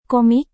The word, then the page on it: komik: indonesian